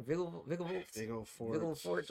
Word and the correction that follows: Vilgefortz: So his name is called, it's spelled V-I-L-G-E. So that's what I'm thinking, the Vigil. – Oh, it's Vilgefortz. That's how they're saying it. – Okay, and then it's F-O-R-T-Z.